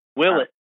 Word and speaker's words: Willett: Willett.